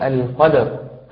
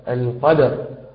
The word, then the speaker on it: القدر: فيعني لو كنت حضرتك حاضر معنا يعني مبكرا، أنا تحدثت في هذه المرة عن مراتب القلقلة، وقلت أن هذه المرتبة تحتاج إلى خفة وسرعة في النطق. فمثلا نقول القدر، القدر، وما أدراك، وما أدراك، هذا من حيث الأحكام العامة.